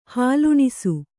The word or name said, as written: ಹಾಲುಣಿಸು